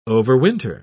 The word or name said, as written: overwinter